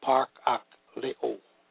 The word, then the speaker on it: parakaleo: Exhortation, the Greek word is parakaleo, pronounced parakaleo, meaning to call, mayor, invite, invoke by exploration, ortation, or consolation, to beseech, call for, comfort, desire.